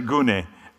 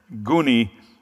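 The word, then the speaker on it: gune (γυνή): I like that actually the Greek word is for wives is guni. It's true. It's true. I can't make this up. It's actually gune is the way you pronounce it, but guni sounds funnier.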